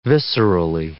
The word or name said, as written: viscerally